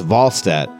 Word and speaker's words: Wallstedt: Actually, it's pronounced Wallstedt. That's how he told us it's pronounced in Swedish during training camp.